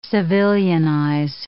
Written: civilianize

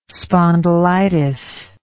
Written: spondylitis

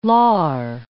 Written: lar

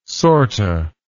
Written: sorta

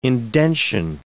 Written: indention

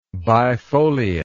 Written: bifoliate

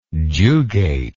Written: jugate

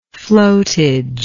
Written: flotage

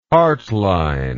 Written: hartline

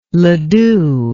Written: Ledoux

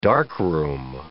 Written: darkroom

